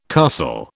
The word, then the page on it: castle: British English